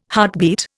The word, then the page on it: heartbeat: Indian